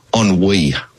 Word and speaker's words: ennui: And I think there's a deep sense of, wait for this, ennui.